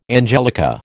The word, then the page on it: angelica: American English